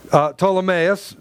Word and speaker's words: Ptolemaeus: And when we had finished our voyage from Tyre, there came Ptolemaeus, the P is silent in that word, greeted the brethren and stayed with them one day.